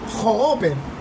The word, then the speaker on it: Hopper: No, se pronuncia Hopper. ¿O no? Claro. – Hopper. – Dennis. – Dennis Hopper. – Dennis Hopper. Es Dennis Hopper.